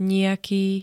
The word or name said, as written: nijaký